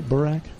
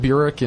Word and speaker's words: Burak: It's like Burr and then Ack. Burak.